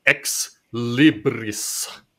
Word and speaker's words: Ex Libris: Uh, I recently got introduced to a game called, and this is this is I'm told the proper Latin pronunciation, uh, which is Ex Libris.